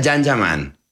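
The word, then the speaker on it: Can Yaman: Se escribe Kanyaman, se pronuncia Janjaman.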